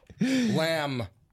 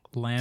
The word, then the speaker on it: lamb: And then you keep pronouncing the B more and more. Yeah. – What are you trying to say? That it's a silent B? – Trying to say? – Lamb.